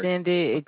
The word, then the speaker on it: Xindi: How do you pronounce it? X-I-N-D-I? – Xindi? Xindi. – Yeah, Xindi, Primate Starship.